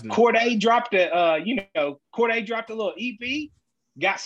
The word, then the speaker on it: Cordae: If you're a fan of Cordae, did I say it right, Juicy? – You did. – Isn't it Cordae? Yeah, Cordae.